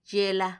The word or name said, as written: yela